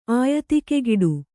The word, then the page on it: ಆಯತಿಕೆಗಿಡು: kannada